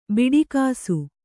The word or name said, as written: ಬಿಡಿಕಾಸು